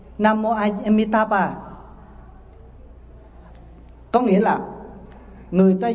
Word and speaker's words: Amitabha: Cái chữ đó đó người Trung Quốc không đọc là Nam Mô A Di Đà Phật, mà người ta đọc bằng cái tiếng phổ thông á là Nam Mô Amitabha. Amitabha.